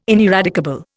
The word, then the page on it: ineradicable: Indian